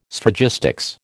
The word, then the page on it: Sphragistics: American English